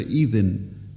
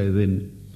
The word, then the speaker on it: Edén: Es muy posible que tal nombre sea inglés, en cuyo caso debería pronunciarse Eden, pero se lo ha chilenizado y se lo pronuncia Edén.